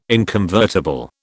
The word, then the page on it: inconvertible: British English